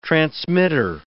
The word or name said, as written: transmitter